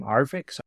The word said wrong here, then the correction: arXiv: Archive. It's spelled ARXIV, but pronounced archive.